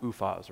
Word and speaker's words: Uphaz: Pronounced like that, Uphaz, right?